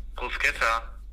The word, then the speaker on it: Bruschetta: Wie wird es ausgesprochen? Bruschetta, Bruschetta oder Bruschett? Bruschetta. Bruschetta? Ja. Das R also auch. Die Bruschetta sagen, Alter. Das R so.